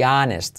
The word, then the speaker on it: honest: It is important to be honest. Saltamos la H, no se hace. Es que no es que saltemos, es que no se pronuncia, ¿vale? De ninguna manera.